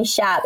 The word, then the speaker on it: Shab: Now I'm like, to people, you can call me Shab, and they still find it difficult. They're like Sham, Shad, Shah.